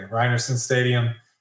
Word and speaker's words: Rynearson Stadium: Look, we're talking Eastern Michigan football, Michael, and Rye Neerson Stadium. Is that what it is? Am I pronouncing that correctly? – You are pronouncing it correctly. Rye Neerson Stadium.